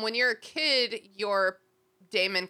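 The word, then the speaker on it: daemon: I believe it's they're obviously spelled like they're pronounced Damon.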